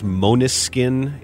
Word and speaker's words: Måneskin: Have you heard of the Italian rock band pronounced Måneskin? It's spelled M-A-N-E-S-K-I-N.